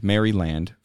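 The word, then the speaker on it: Merrie Land: The song is called Maryland, but it's spelled M-E-R-R-I-E, Land, Maryland, from The Good, The Bad, and The Queen.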